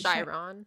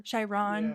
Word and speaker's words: Chiron: I just think it's and I think following this character of Chiron, I'm saying his name right. Is that correct? – I think it's Chiron. – Chiron. Yeah. Chiron, I'm sorry.